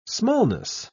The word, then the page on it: smallness: British English